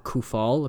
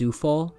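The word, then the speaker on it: Souček: Apparently it's not Kufal. Apparently it's Sufal.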